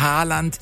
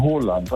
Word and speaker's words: Haaland: Erling Haaland ist falsch, ne? – Ja, das ist absolut falsch. Er wird ausgesprochen Haaland, also mit O. – Ja. – Haaland, und zwei A's werden als O ausgesprochen und ein A mit einem Kringel oben drauf ist eigentlich auch eine Aussprache, ein O. Also das heißt nicht Ahlesund, das heißt Ålesund.